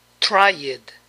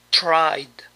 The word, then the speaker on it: tried: Para todo verbo regular que termina con el sonido de vocal, la terminación ed se pronuncia d, nada más. No se pronuncia id. En otras palabras, no es tried, sino tried.